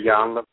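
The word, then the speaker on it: Iyanla: And excuse me if I have her name wrong, but I think her name is Ananya. How is that correct? – It's Yanla and then Yanla.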